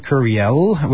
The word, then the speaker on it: courriel: That's spelled C-O-U-R-R-I-E-L. La Commission Générale de Terminologie, overseer of the French language in France, has adopted the term 7 years after it was first used by University of Montreal literature professor Jean-Claude Goudin. The word courriel, and you have to say it like that, too, each time, recently appeared in the Language Commission's official publication, and use of the term will be mandatory throughout the French government.